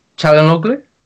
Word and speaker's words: Çalhanoğlu: Çalhanoğlu? – That's it.